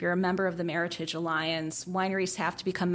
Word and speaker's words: Meritage: So Meritage was created combining the words merit and heritage, and it's definitely supposed to be pronounced Meritage, not Meritage.